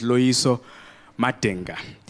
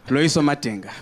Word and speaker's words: Loiso Madenga: Yes, my name is Loiso Madenga, which they mispronounce when I perform in New York. Simple African name, no sounds, no one has never heard in that word before. Loiso Madenga, but they call me Loiso Mandingo.